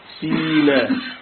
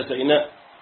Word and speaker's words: سيناء: سيناء. يبقى أنا عندي كده سيناء تنطق بكم طريقة؟ بثلاثة: سيناء وسيناء وسينين.